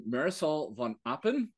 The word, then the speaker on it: Marisol von Appen: I have the privilege of introducing Marisol von Appen.